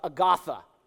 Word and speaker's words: Agatha: For all of those who are not Jamaican, you pronounce Agatha Agatha. And if you don't believe me, just ask Miss Riley. She'll tell you.